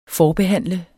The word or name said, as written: forbehandle